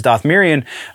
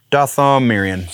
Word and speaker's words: Dathomirian: Very specifically, this Jedi that potentially has ties to Darth Vader and also potentially is Dathomirian. I know I just said that wrong. I'm going to put that one lower. The Dathomirian? Dathomirian, sorry.